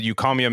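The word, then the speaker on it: Eucommia: It's mostly eucomia. Is that how you say it? – Eucomia. Yeah.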